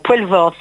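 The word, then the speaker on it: Poelvoorde: Voilà, et Benoît Poulvord, évidemment. Poulvord, pardon, j'aime pas écorcher son nom comme ça.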